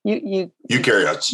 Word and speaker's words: eukaryotes: There's another type of cell that was growing called eukaryotes. – Eukaryotes. – Eukaryotes. Thank you.